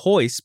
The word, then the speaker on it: Royce: And again, for those new initiates, actually, it would be Royce because in Portuguese, the R is pronounced like an H.